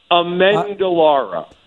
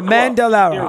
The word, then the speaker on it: Amendolara: Amendolara. But you were Amendolara.